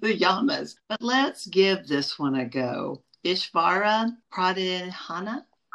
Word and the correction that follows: Pranidhana: Close. Ishvara Pranidhana.